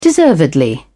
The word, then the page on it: deservedly: British English